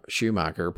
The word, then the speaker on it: Schumacher: And I feel he's probably right that if it wasn't for Schumacher putting him, did I say that right? – Yeah, you got it.